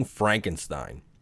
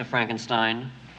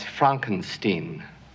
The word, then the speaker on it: Frankenstein: Me and Joey from So Wizard, man, we throw fucking quotes around this shit and gifts all the time from Young Frankenstein. – I have one question, Dr. Frankenstein. – That's Frankenstein. – I beg your pardon? – My name. It's pronounced Frankenstein.